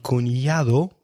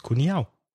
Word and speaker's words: cuñado: This feature of dropping sounds, missing sounds, is similar in Spanish with words like cortado, which is sometimes pronounced cortao, and cuñado, which is sometimes pronounced cuñao. Yeah.